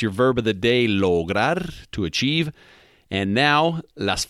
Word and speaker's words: lograr: So anyhow, today is ayer, and ayer is spelled A-Y-E-R, ayer. Okay, now the verb of the day today is lograr, and lograr means to achieve, and the way it is spelled is L-O-G-R-A-R, lograr.